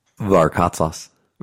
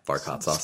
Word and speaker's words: Varkatzas: Varkatzas. – Varkatzas. – Yeah. – Really? – Yeah, Varkatzas. – Spell it. – V-A-R-K-A-T, Z as in zoo, A S as in Sam.